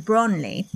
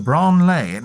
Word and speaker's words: Branle: One of the prominent forms of this sort of moving left dance was known as the Branle. – The Branle. Now, how do you spell that one? – B-R-A-N-L-E. – Okay. – And it's from French, meaning to sway.